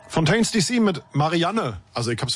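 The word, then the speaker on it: Fontaines D.C.: Und du hast es wirklich falsch ausgesprochen, weil es ist Fontaines DC.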